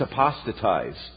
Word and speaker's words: apostatized: And Mike said, no, it's apostatized. Check it out. So I Googled it, and Mike is right. Um, that is actually the correct pronunciation. It's apostatized.